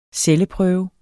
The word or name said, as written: celleprøve